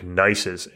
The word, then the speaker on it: gneiss: So at the roots of the of the Tetons are what's called these metamorphic gneisses. And gneiss is spelled G N E I S S.